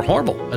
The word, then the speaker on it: Hormel: introduced by Hormel, or Hormel, as the locals say.